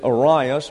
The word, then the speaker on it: Arius: All of my pronunciations, by the way, come from the Webster's New Biographical Dictionary, the Webster's New Geographical Dictionary, or from Unger's Bible Dictionary. And that's the standard I use, and that's how I get my pronunciations. And this is Arius because that's what Webster said.